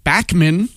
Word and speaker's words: Bachman: BTO, Bachman Turner Overdrive, or as they say in Canada, Bachman.